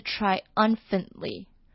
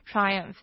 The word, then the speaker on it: triumphantly: 那它的这个副词，也就是在句子里面用到的这个字是 triumphantly。 Triumphantly，它跟 triumph 的重音不太一样，然后 u 的发音也不太一样。 Triumph 呢，这个 iu 的 u 呢是发 e 的音，triumph。那在 triumphantly 呢，这个 u m 呢是连在一起念，所以会变成 triumphantly。